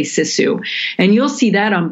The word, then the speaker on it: sisu: Well, in Finnish, it would be pronounced more or less like sisu, but